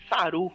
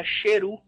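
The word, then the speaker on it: Sheru: O nome dele não era Saru, era Cheru.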